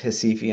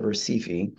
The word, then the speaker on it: Recife: and it's spelled Recife, but R's are kind of pronounced like H's, so it's pronounced Hesifi instead of Recife.